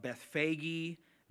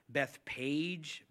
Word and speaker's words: Bethphage: Now, that word can be pronounced a couple of different ways. It could be, you may have heard pastors pronounce it Bethphage, Bethpage, Bethpagey. I don't really know exactly which one. To me, Bethphage looked the best. Phagey sounded weird to me, and so Bethphage, P-H, kind of makes an F sound.